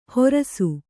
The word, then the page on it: ಹೊರಸು: horasu; kannada